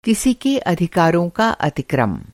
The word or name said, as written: अतिक्रम